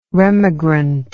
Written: remigrant

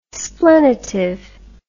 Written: splenitive

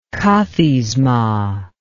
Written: kathisma

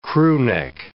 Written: crewneck

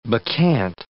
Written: bacchant

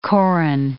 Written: korun

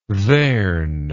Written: theirn

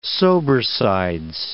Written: sobersides

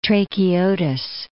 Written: Seleucia Tracheotis

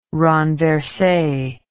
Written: renverse

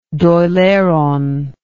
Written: Dail Eireann

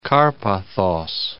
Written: Karpathos

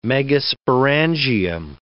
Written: megasporangium

